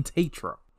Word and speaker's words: Tetreault: Jackson Tatro. And you look at his name, it's TETREAULT. When I looked at his name, I was like, that sounds like Tatro? That's how you say his name?